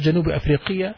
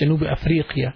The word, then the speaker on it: جنوب أفريقيا: أنا من مصر أنا من جنوب أفريقيا not جنوب أفريقيا it's جنوب أفريقيا that's how you pronounce it